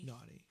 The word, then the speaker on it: Nonni: And that's called Nani? – Nani. Yeah. – Like, what's, how do you spell that? – N-O-N-N-I, like your grandma.